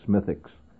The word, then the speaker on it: Smithwick's: But what you generally see in the pubs, of course, you find Guinness Stout being drunk, Smithwick's, actually spelled Smithwick's, but it's pronounced Smithwick's, which is a very, I think, an evil drink.